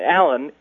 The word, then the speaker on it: Alan: As for the name Alan, it's spelled A-L-A-N, not A-L-L-A-N or A-L-L-E-N.